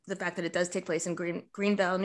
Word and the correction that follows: Granville: New York, right? Granville, thank you. I knew I was mispronouncing it.